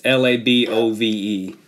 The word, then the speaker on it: LaBove: Okay, and it's L A B O V E Carl LaBove. Cool. – Above with an L, correct.